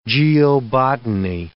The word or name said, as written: geobotany